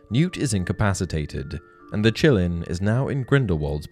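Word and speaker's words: Qilin: Number one, it's pronounced chillin, but it's actually spelt with a Q Q I L I N.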